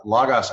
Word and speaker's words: Logos: Logos and Logos are actually both correct. And really, it depends on which school you went to. Some schools use the Erasmian Greek pronunciation. Some use a different Greek pronunciation. Some people actually prefer to use modern Greek pronunciation. So the great news is we'll answer to all of them, and neither are wrong.